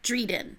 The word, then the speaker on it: Drieden: spelled D R I E D E N, mm-hmm. and it rhymes with Sweden. – Okay, so it is the royal family of Drieden.